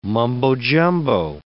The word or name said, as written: mumbo jumbo